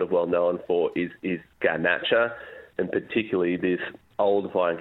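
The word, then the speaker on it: Garnacha: Yeah, so it's Grenache, but it's the Spanish, that's the way the Spanish call it, Ganache.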